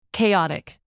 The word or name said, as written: chaotic